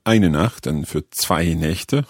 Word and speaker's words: Nächte: So the vowel sound changes in there. Die Nacht and zwei Nächte. – Nächte. Die Nacht and zwei Nächte. So the plural of a German word is formed by changing the sound.